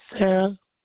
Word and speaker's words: Sarah: Sarah. – All right. And that's spelled S-A-R-A-H? – Yeah, that's good.